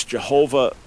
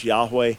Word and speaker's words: Yahweh: Christians, in some translations, read it as Jehovah, which was a misnomer. The more likely pronunciation is Yahweh.